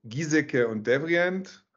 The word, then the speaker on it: Giesecke und Devrient: Er war Strategiechef bei Bosch Siemens Hausgeräte, er war Arbeitsdirektor bei Giesecke und Devrient.